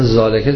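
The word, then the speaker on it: ذلك: مثلا زالکه تلفظ میکنن زلکه نوشتن،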